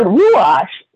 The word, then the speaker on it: Ruach: Now, the word Ruach is spelled R-U-A-C-H, means breath or spirit.